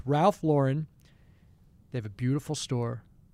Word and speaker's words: Lauren: By the way, it's Ralph Lauren. It's not Ralph Lauren. I've been saying it wrong my entire life.